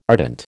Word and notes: ardent: American English

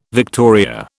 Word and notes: victoria: British English

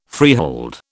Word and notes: freehold: British English

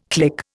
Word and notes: click: Indian

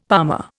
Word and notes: palmer: Indian

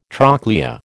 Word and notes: trochlea: American English

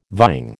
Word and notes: vying: American English

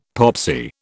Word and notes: popsy: British English

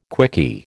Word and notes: quickie: American English

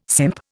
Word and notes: simp: Indian

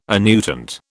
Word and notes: annuitant: British English

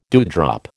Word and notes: dewdrop: American English

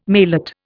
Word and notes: maillot: Indian

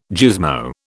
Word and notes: gizmo: British English

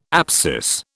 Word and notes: apsis: British English

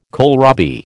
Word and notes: kohlrabi: American English

